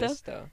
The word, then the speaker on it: Mephisto: Mephisto? – Mephisto.